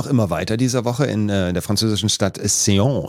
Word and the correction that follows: Caen: Ich hoffe, sie wird so ausgesprochen. – Nee. – Caen. Entschuldigung, Caen.